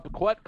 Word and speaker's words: Bequette: Yeah, Bequette, close enough.